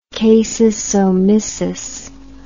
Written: casusomissus

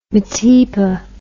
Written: metepa

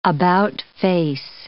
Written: about-face

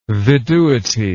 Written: viduity

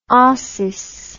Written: ossis

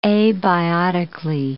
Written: abiotically

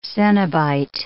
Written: cenobite

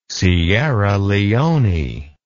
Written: sierra leone

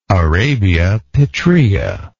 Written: arabia petraea

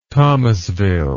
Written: thomasville

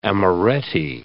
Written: amoretti

